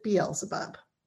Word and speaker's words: Beelzebub: In English, we pronounce it Beelzebub.